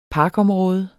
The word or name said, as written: parker